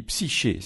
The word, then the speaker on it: Psyche: On va partir dans l'espace avec une mission spatiale, la mission, on dit, allez, je le dis, psyché, c'est ça. On peut dire psyché aussi, hein. vous n'aurez pas, vous n'aurez pas faux. Effectivement, on peut prononcer les, deux apparemment, euh, psyché, psyché.